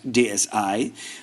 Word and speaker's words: DSi: Ich habe gesagt DSi, weil ich dachte, das i wie iPod oder iPhone oder iMac, iBook, ja. Aber in der Werbung von Nintendo, die im deutschen Fernsehen läuft, wird gesagt DSi. Und da ich denke, dass die Jungs von Nintendo wissen, wie sie ihr Produkt gerne ausgesprochen haben möchten, nenne ich es jetzt am besten auch DSi.